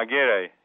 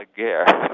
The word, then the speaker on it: Aguirre: Lope de Aguirre? – Yeah, Aguirre. Yeah. – Aguirre.